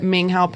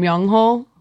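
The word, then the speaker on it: Myungho (명호): I like fairly recently learned that Minghao pronounces his name Myungho. So just if anyone was wondering who I was just talking about just now, the eight, his real name is Myungho. And it broke my heart that I'd been saying it wrong for so long.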